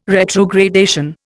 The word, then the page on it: retrogradation: Indian